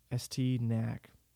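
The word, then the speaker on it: Estee Nack: I believe I'm saying that right. I'll spell it. ESTEE space. NACK. ST Knack.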